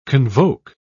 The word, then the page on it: convoke: British English